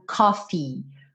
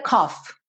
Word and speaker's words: coffee: Esses dois E's no final pedem esse som. Então nunca diga cough, ok? Cough parece tosse. cough, cough, cough. Então coffee, bota essa sílaba aí no final.